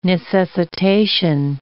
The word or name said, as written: necessitation